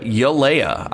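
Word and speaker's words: Galeai: You know, besides Preston Smith, you've got Rashawn Gary, Jonathan Garvin, and then from there they elevated Tipa Yalea. I didn't realize it was pronounced with a Y. It's actually not a G, not a hard G.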